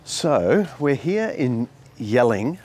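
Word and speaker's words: Jelling: Dad, it's yelling, Dad. – What do you mean? It's gelling. It's pronounced... It's got a J on it. – But that's not normal. You say Y for Germanic languages.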